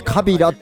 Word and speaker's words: 川平: 川平と書いてカビラって読みますと来ております。